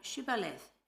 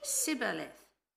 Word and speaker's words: Shibboleth: And when he said, "No," they said to him, "Then say, Shibo, Shibboleth." And he said, "Sibboleth," for he could not pronounce it right.